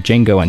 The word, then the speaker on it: Django: D-J-A-N-G-O. The D is silent.